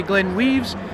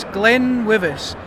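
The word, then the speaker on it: GlenWyvis: It's a very difficult one to pronounce. Some people say Glenwivis, some people say Glenweaves. But it's Glenwivis. Our history comes from an old lost distillery in the local area called Benwivis.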